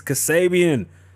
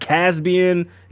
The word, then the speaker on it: Kasabian: It's Kasabian, not Caspian, Xavier, none of that.